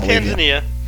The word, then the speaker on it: Tanzania: Probably is Tanzania. It's in Africa, dude. – Is it? – Yeah, it's Tanzania, for sure. – Yeah, I've been mispronouncing that. It's Tanzania. I've been butchering that bad.